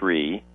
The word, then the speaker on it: Sri: Of course, we've been referring a couple of times this evening to a guide to the new translation of the Mass by Edward Shree. The last name is spelled S-R-I.